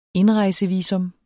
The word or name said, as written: indrejsevisum